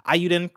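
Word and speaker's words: Eiyuden: Ayuden Chronicle Rising, that came to PS4 and PS5 May 10th.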